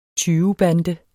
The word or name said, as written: tyvebande